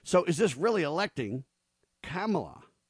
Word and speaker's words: Kamala: So you got this Kamala Harris is how you're supposed to pronounce it. Kamala. Kamala.